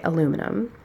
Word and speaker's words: aluminum: You've written it here on this, you, it's not your fault, listener, but here on the thing I'm looking at, it's spelled aluminium, but I definitely only ever say aluminum.